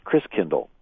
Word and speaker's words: Christkindl: And the German pronunciation of Christ child is Christkindl. Christ meaning Christ, and Kindle like kindercare and kindergarten means child.